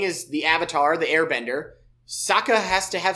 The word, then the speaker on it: Sokka: And there's a lot of development with Sokka, which that's how he's pronounced in the show.